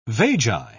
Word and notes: vagi: British English

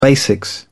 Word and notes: basics: British English

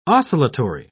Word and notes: oscillatory: British English

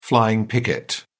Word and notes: flying picket: British English

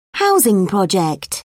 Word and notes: housing project: British English

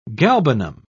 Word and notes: galbanum: British English

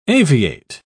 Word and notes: aviate: British English

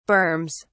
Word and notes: berms: British English

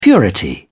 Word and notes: purity: British English

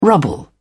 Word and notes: rubble: British English

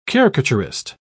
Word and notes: caricaturist: British English

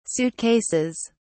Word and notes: suitcases: British English

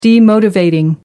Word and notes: demotivating: American English

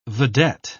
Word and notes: vedette: British English

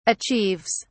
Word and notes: achieves: British English

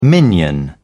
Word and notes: minion: British English

